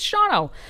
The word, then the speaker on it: Shawano: We're talking about Shawano, spelled Shawano for any of you who are not outside of the state. – Outside of the state, yeah. Would probably pronounce it as such. It's Shawano.